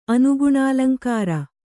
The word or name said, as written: ಅನುಗುಣಾಲಂಕಾರ